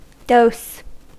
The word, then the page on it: dose: /dəʊs/; /doʊs/